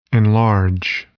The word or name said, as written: enlarge